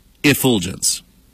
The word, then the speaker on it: effulgence: Infulgence. I'm sorry. Effulgence.